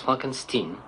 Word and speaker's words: Frankenstein: Non, mon nom se prononce Frankenstein.